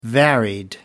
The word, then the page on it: varied: American English